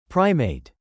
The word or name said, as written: primate